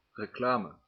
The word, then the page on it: reclame: /ɾe.kla.mə/